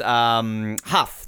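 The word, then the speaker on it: Hough: Nicholas Huff, that's how you say his name, isn't it? Huff Puffin, yep.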